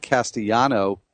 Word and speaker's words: Castellanos: I'm happy to share the thunder because you talked about Nick Castellano, who you pronounced that way. – According to Baseball Reference. – According to Baseball Reference, yes. – There's no S on the end. – I was going to go Castellanos. There is an S on the end, but you don't say it.